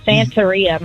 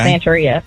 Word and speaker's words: Santeria: It kind of has the beginning starting out like Sinatra. Santeria, my bad, I said it wrong. – Okay. – Santeria.